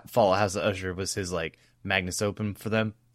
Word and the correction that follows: magnum opus: And I said that wrong. Magnum opus. For them, not a magnus opum.